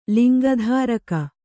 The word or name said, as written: ಲಿಂಗಧಾರಕ